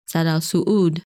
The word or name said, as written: Sadal Suud